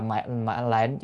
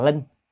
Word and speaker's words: lệnh: Lệnh là mệnh lệnh, chữ đó thì đọc là mệnh lệnh chứ không phải đọc là linh, nó chữ linh với chữ lệnh là một, nhưng